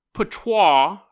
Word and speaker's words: Poitiers: We're going to Poitiers, France, and you guys are shocked that I pronounced that right. It's the only reason I pronounced that right because I was in a play. I was King Richard the Lionheart, and I kept calling it Poitiers, and they're like, it's not Poitiers, it's Poitiers, which is stupid.